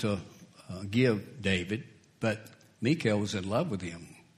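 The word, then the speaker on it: Michal: Spelled like Michael, but I'm told it's pronounced Mikael.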